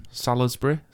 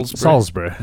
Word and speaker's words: Salisbury: Salisbury? – No. – Salisbury. – There you go. Second attempt. – I say Salisbury. Yeah. But then I say... – I think people who say Salisbury are taking the piss, surely, because it's Salisbury.